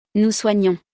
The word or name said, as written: nous soignons